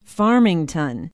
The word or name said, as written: Farmington